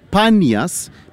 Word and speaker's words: Panias: Banias is a mispronunciation of the Greek word Panias because there's no P in the Arabic alphabet.